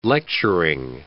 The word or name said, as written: lecturing